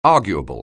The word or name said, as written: arguable